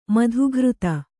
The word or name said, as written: ಮಾತುಗಾರ್ತಿ